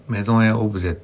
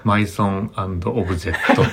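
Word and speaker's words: Maison&Objet: まだ僕あれメゾンエオブジェって読めなくて、マイソンオブジェットって読んでた時ですね。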